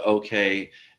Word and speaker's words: OKeh: Or I should say we relaunched the OK, and that's spelled O-K-E-H.